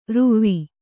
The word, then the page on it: ರುವಿ: Kannada